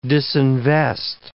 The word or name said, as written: disinvest